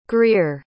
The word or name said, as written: greer